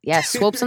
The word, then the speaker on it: Swopes: Well, that's how I tell people how to say my name. Because they always say Swoops. Swopes, I get sometimes. Yeah. I'm like, no, it's with Slopes.